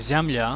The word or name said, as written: ziamla